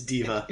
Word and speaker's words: D.Va: It's D.Va.